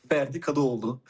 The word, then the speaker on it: Ferdi Kadıoğlu: Ferdi Kadıoğlu.